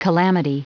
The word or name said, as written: calamity